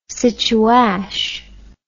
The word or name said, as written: situash